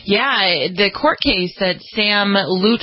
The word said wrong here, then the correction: Lutfi: oh, I can never say his name right. Sam Lutfi had sued her parents for defamation of character and breach of contract.